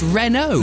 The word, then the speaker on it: Renault: Thanks, Stu from Scarborough, who's texted to say, Dave, it's spelt Renault, but commonly pronounced Renault. – No, give over. – It's Renault. – Stu. That's Renault. – Is it a silent T? – Yeah, apparently I didn't realise that, Stu.